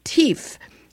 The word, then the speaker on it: Teef: There's a quite extraordinary product called Teef, which is quite funny because it's spelled T-E-E-F, like teef, like a little kid would say teef if they had a lisp.